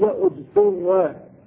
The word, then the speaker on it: اضطر: قال الله تعالى فمن اضطر، فمن اضطر، إذا ابتدأ بالفعل قيل قيل اضطر بضم الهمزة والطاء.